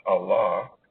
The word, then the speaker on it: Allah: Allah, speaks to us in the book known as the Holy Quran.